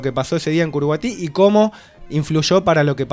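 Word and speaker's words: Curuguaty: No sé si lo pronuncio bien. – Perfecto. – Es Curuguatí, ¿no? – Exactamente.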